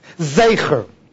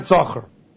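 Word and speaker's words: Zecher (זכר): Of course the word is pronounced Zecher, not Zocher.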